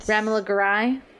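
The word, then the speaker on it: Romola Garai: Ramila Garai.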